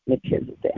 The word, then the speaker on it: Melchizedek: Melchizedek. – Melchizedek.